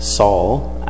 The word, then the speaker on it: Saul: And actually, the Greek name Saul actually rhymes with Paul, just like in English.